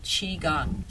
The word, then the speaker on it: Qigong: And one of the things that I love to do that I work with my clients, and that is using something called Qigong, spelled Q-I-G-O-N-G. Sometimes it's spelled C-H-I-G-O-N-G.